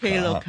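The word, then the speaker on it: Klook: 咁你哋公司呢我都唔係好敢发音，究竟係 K-Look 啊呢个 Clook 啊其实剛才呢我哋见到介绍呢都係 K-Look。 – K-Look, 係啊。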